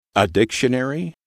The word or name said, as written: a dictionary